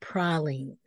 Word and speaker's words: pralines: They're actually pronounced pralines. – Pralines. Okay.